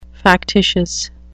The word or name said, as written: factitious